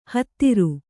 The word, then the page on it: ಹೋತೃ: kannada